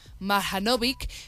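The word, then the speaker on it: Marjanović: Boban Marjanovic, muy bien, Saray, vale, bien, gracias.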